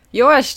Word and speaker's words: jó: Jó Yeah. – Did I say that correctly? – Yes, that that was pretty much perfect. – Jó listeners.